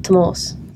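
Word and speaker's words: Tomás: Sure, we've gone through many iterations of how to pronounce your name, because there's a little accent on the A. – On the A, yeah. – And that means it's pronounced Tomas.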